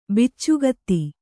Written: ಬಿಚ್ಚುಗತ್ತಿ

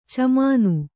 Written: ಛಮ್ಮನೆ

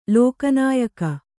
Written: ಲೋಕನಾಯಕ

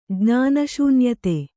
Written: ಜ್ಞಾನಶೂನ್ಯತೆ

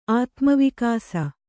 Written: ಆತ್ಮವಿಕಾಸ

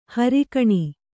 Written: ಹೊರಗಣ

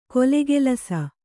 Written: ಕೊಲೆಗೆಲಸ

